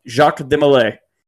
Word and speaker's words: Jacques de Molay: I pronounce that like Norm MacDonald doing a joke. Jacques de Molay.